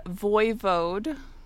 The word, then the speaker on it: Voivode: I looked up how to pronounce this word. The Voivode of Transylvania. And Voivode.